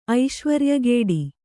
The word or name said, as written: ಐಶ್ವರ್ಯಗೇಡಿ